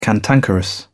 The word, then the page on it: cantankerous: British English